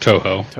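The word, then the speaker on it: Touhou: It's pronounced Toho. – I was going to say it is Toho.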